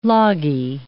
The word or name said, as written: loggy